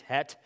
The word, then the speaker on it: het: The Hebrew letter het, looks like hath maybe to you, or het maybe is how you pronounce that, I guess, not hate, het,